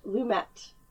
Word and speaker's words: Lumet: Also, I was mortified to discover that it is pronounced Lumet, Sidney Lumet. – I've been saying Lumet my entire life as well.